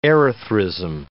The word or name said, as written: erythrism